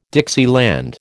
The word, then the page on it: Dixieland: American English